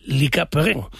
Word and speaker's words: Lucas Perrin: En un día en el que el Sporting está ultimando los detalles para anunciar al defensa francés Lucas Perrin, así se escribe, aunque creo que en francés se dice Lica Perrin. Yo creo que para todos va a ser Perrin, pero bueno, hay que decir Lika Perrin.